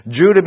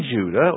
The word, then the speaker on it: Judah: Verse 2 also tells us that it was in Judah or Judah. They always pronounce the J as a Y.